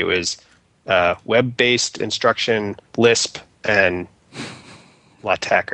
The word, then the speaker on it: LaTeX: LaTeX. Technically, it's tech is really supposed to be tech, but nobody says it that way.